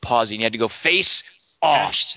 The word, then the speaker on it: Face/Off: Not how you say that. Say the name properly. – Face Off. – No. – Face/off. – Dingus, do it for him.